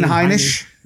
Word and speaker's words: Heinisch: Then from there, his most recent victory was, how do you pronounce his name? Is it Heinisch? – Ian Heinisch. – Yeah, Ian Heinisch.